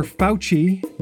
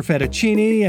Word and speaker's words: Fauci: Second, it's pronounced Dr. Fauci, not Dr. Fettuccine, and certainly not Dick Fawcett, so we apologize for that as well.